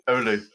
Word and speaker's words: Oulu: He's got two master's degrees, one from the University of Durham and another from the University of Oulu. Am I saying that correctly? – Oulu, yeah Oulu.